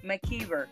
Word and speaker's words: McKiver: McKiver, sorry about that.